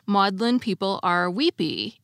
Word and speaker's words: Magdalen: And second, I told you that the author of the piece about tea was from Magdalen College, which is not pronounced Magdalen, but is pronounced Maudlin. It's Maudlin College.